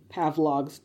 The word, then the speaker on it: Pavlov: Pavlov's dog metaphor. And because I love her being like, he, like, mispronounced it. She's like, it's Pavlov.